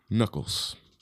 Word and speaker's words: Nuckolls: Knuckles. – Old Knuckles? – Old Moose Knuckles? – I believe that's how it's pronounced. It's spelled N-U-C-K-O-L-L-S. – M-I-C-K-E-Y? M-O-U-S-E? – M-O-U-S-E.